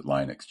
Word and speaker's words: Linux: I pronounced it Linux, just be like Richard Stallman there.